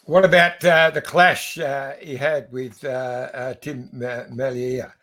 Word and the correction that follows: Merlier: Okay, I'll give you a pronunciation lesson. It's for everybody there. It's Tim Merlier. It's like the ear on the side of your head, Merlier. Because he's Flemish, he's not French, he's not Merlier, he's Tim.